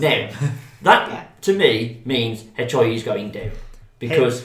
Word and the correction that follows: Heihachi: He just constantly mispronounces Heihachi. – Yes, Hechoi, whatever he's called, the big guy is going down. – Big H. – You're adding extra letters.